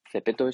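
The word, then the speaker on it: Zepeto: Y Zara se ha metido de la mano de Cepeto. Cepeto es una aplicación que se escribe con Z, lo podéis buscar en, creo que está para Android y para, para para ellos está seguro.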